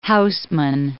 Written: houseman

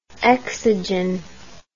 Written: xogen

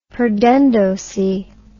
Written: perdendosi

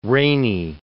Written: Reni

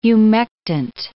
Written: humectant

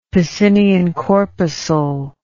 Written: pacinian corpuscle

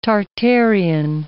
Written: Tartarian

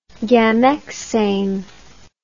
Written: gammexane

